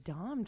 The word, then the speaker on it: Dahm: Erica Dahm, D-A-H-M. She's turning 40. One of the Dom triplets.